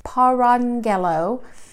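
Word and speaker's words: parangello: And I know that it looks like, if you look it up, it looks like it doesn't have an N in it, but the pronunciation does have an N in the middle of that word, parangelo.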